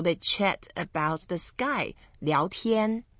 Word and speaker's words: 聊天: So when you put board and chat with the sky together, then you pronounce as 聊天. – 聊天.